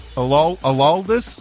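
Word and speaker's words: Aroldis: Araldis.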